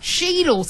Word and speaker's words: Cheadle: Cheadle. So it's spelled C-H-E-A-D-L-E.